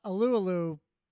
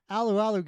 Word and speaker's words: Alualu: So Alualu, you know, or Alualu, I'm sorry, I pronounced it wrong. Alualu got injured early in that game,